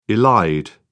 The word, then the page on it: elide: British English